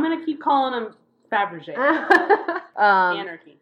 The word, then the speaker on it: Fabergé: Carl Fabergé. I always pronounce it Fabergé. – Me too. – But that's actually wrong. It's Fabergé.